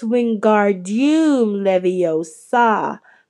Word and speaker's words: Wingardium Leviosa: Wingardium Leviosa, he shouted, waving his long arms like a windmill. You're saying it wrong, Harry Hermione heard Hermione snap. It's Wingardium Leviosa. Make the gar nice and long you do it then, if you're so clever, Ron snarled.